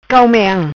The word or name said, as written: Cao Meang